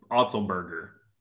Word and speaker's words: Otzelberger: How do I pronounce that? – It's it is indeed Otzel Otzelberger. – Otzelberger. Okay, that's what I thought.